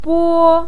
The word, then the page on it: bo1: pinyin